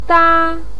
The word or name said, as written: da1